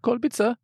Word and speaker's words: Kolbitzer: Kolbitzer. You have to say it with a German accent.